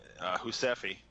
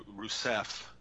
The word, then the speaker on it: Rousseff: And especially Husefi, or I'm sorry, that's the Brazilian way I pronounce it, Rousseff.